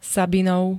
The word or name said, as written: sabinov